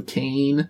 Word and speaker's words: Caen: Well, I mean, it's spelled C-A-E-N, so Cain, so not quite C-A-I-N-E. – Oh, man. – It's Cain or Cain.